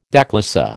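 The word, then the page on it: déclassé: American English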